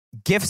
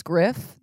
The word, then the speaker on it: Giff: Giff Smith, and it's the hard G. – Yeah. – And Patrick Claibon suggested that should just be his nickname, hard G. – I thought that his name was Griff this whole time.